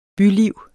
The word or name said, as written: byliv